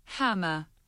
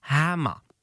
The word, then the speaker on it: hammer: Chytrá umělá inteligence, nebo možná už netolik umělá, říká Hammer. – Hammer. Zkus to vyslovit. – Hammer. – No vidíš, tak je to krásně jde. Ani tam není to R.